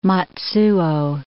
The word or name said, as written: Matsuo